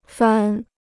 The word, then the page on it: 番: fān